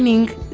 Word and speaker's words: ning: Ese NING se escribe N-I-N-G. – Como ninguno, pero sin el uno.